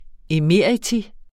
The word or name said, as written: emeritus